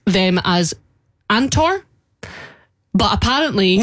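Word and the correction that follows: ANOTR: No, it wouldn't be Antor, because it's A-N-O-T-R. – But no, it's apparently pronounced another.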